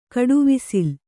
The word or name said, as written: ಕಡುವಿಸಿಲ್